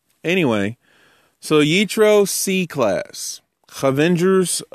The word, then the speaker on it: Yitro: Pun intended, because it's Parsha Yitro, which rhymes with nitro.